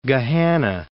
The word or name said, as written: Gahanna